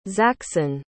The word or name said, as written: zaxson